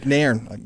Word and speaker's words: Nairn: Christian Nairn, and that is right. – Christian Nairn. – Nairn.